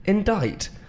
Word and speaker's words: indict: I-N-D-I-C-T. – Oh, God. Okay, oh, but, wow, that's a good one. – Yeah, that silent C.